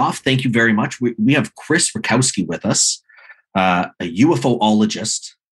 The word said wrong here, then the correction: ufologist: UFOologist, I'm saying that correctly. – No, I'm saying it incorrectly. – Ufologist.